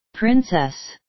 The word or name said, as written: princess